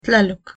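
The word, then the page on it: tlaloc: Nahuatl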